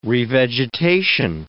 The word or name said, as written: revegetation